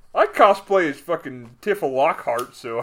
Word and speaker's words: cosplay: They look like 1987 Coplay. Cosplay. Is that how you say it? Cosplay? – Yeah. Yeah.